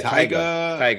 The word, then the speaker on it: Tyga: Is it Tyga? Tyga? – Oh, yeah. – Tyga? – Former with his new video.